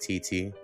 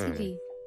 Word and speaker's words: Tetee: Spelled T-E-T-E-E. So I pronounce that TT. – Yeah, it's TT.